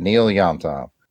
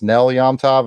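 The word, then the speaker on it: Nel Yomtov: Actually, I'm just saying that to drive comic foil nuts because I think he mentioned in the chat that it's probably pronounced Nell Yamtov, and I've been reading it wrong for 18 episodes, and I'm not going to stop now.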